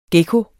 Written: gekko

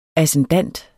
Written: ascendant

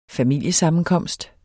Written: FAMILIESAMMENKOMST

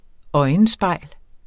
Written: øjenspejl

